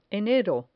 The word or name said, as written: enero